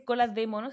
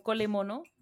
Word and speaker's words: cola de mono: Sí, cola, en realidad no se dice cola de mono, es como cole mono.